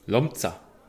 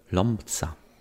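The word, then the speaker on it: Łomża: So, da ich das letzte Mal total fail unterwegs war und total peinlich, gar nichts wusste, wie wird das ausgesprochen? – Lomza. – Lomza. – Lomza.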